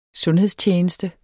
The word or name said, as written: sundhedstjeneste